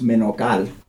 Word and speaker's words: Menocal: In Spanish, it's Menocal. – Menocal? – Yeah, so okay. I go for the closest approximation. – Which is? Menocal. Menocal? – Yeah. – Okay. – Yeah. – All right. – But I've long ago accepted that it's easier just to get things to English pronunciation when you're in this country.